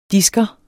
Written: disker

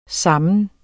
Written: sammen

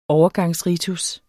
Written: overgangsritus

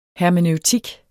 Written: hermeneutik

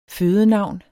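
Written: FØDENAVN

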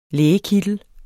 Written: lægekittel

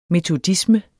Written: metodisme

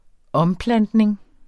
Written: omplantning

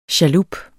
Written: chalup

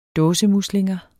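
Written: DÅSEMUSLINGER

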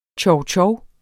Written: chowchow

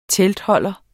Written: teltholder

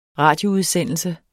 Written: RADIOUDSENDELSEN

